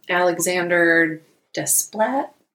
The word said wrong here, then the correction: Alexandre Desplat: I'm sure there's a cooler way to pronounce that, and I'm butchering it. – But... Desplat. – Alexandre Desplat.